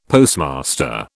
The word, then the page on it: Postmaster: British English